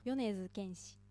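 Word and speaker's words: Yonezu Kenshi (米津玄師): Yonezu Kenshi. – Oui, on commence par Yonezu et puis Kenshi.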